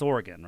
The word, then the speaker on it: Oregon: It's Oregon, right? – No, you said it right.